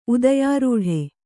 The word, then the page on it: ಉದಯಾರೂಢ: udayārūḍha